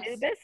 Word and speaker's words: Anubis: Anubis was the deity, dog of deity, and guided souls to the other world.